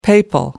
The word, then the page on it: papal: American English